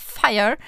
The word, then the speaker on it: Feyer: It's not Freier, but Fire, which is spelled F-E-Y-E-R, and it's actually it's a part of Nova, or They actually belong together.